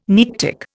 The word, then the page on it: Nearctic: Indian